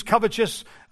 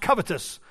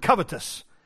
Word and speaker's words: covetous: I'm in the habit of saying it that way, so you'll hear me talk about this morning and you'll hear me use covetous. I don't mean to, the word is actually pronounced covetous. Whether that's important or not to you, that is the right word, covetous.